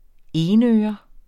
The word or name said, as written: enøre